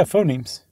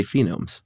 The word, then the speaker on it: phonemes: Yeah, phonemes. – I always want to say phenomes. – I know. – I just goofed up and Josh corrected me. So we edited out that part. – Phonemes.